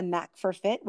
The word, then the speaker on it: NAK4FIT: Laine is a licensed personal trainer through the National Academy of Sports Medicine and the owner of her own fitness company, NAK4FIT, which is pronounced a NAK4FIT, which we need that knack, and hopefully she'll help us to get more fit.